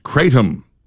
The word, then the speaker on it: kratom: Thomas says, it's pronounced Kratom. Kratom. – I've heard it pronounced both ways.